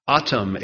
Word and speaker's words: Atum: Ptah, that's spelled P-T-A-H. Ptah, in the Egyptians' minds, created the moon and the sun and the earth. Atum, A-T-U-M, the sun god, was also in control of the sun.